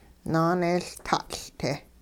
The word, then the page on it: naaneeyLtaatL'-te: hupa